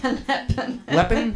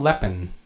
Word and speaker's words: Le Pen: Le Pen? Is that her name? – Le Pen. – Le Pen? Margaret Le Pen or something? – Yeah. – Lupin? – Oh, man.